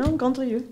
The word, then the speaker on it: Grandrieu: No, Grandrieu.